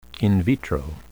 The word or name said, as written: in vitro